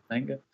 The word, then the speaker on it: manga: That's the manga or however you say it. – I think you said it right the first time. Right. Manga.